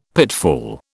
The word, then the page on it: pitfall: British English